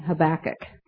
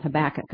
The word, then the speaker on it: Habakkuk: and I think I'm going to be saying Habakkuk.